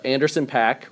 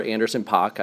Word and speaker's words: Anderson Paak: Um, NxWorries consists of uh, Anderson Paak or Anderson Pock. I guess both pronunciations he's okay with.